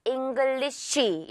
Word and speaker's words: English: SH 주의를 해서 English 마지막엔 살짝이죠. English 이렇게 정확하게 하지 말고요. English English. 오케이?